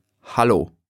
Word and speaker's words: hallo: Hello is hallo. – Oh, that sounds almost the same. The only difference I noticed is the first vowel sound, a, instead of e. Can you say that again? – Sure. Hallo. Hallo. Hallo.